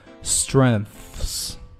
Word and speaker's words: strengths: s 后面有一个 k, 这里面受到 s 的影响，所以说呢不送气，读得像 g 一样 discover opportunities And 这就弱读了 And decide 后面的的读得很轻，后面停顿一下 Whether the company has the strength 同样也是 s 后面有一个 dr 它是一个辅音的组合，然后不送气发得像 dr 一样 strength 同时后面 th, 这个发音的舌尖要放在上下齿之间啊，咬舌完了后面还有一个 s 所以说应该是 strengths